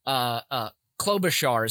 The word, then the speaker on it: Klobuchar: Klobuchar is how you pronounce Senator Amy's name.